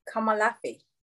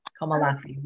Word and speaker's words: Komolafe: Am I saying that wrong? So pronounce it properly. – Komolafe. – That's really pretty when you say it the way you say it. Komolafe.